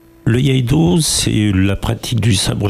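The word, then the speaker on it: Iaido: Alors, du coup, votre école, du coup, c'est le Yeido. Je prononce bien ou pas ? – Yeido, tout à fait.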